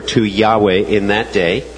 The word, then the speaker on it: Yahweh: Which would be pronounced Yahweh or Jehovah.